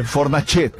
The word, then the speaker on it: Fornacete: Com o que rima Fornacete? – Peraí, deixa eu escrever aqui Fornacete no meu translator aqui. – Mas o cara não mandou como se pronuncia? – Rima com croquete.